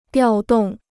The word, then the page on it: 调动: diào dòng